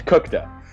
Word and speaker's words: Kuchta: Uh, it's pronounced fire truck. It's just spelled. anyway, no, it's it's Cookta.